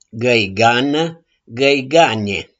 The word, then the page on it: GĂIGANĂ: GĂIGANE